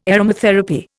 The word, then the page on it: aromatherapy: Indian